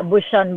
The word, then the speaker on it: Bouchon: Yeah, Bouchon Bakery.